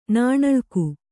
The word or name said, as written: ನಾಣಳ್ಕು